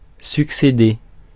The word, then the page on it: happen: American English; /ˈhæp.ən/; British English; /ˈhapən/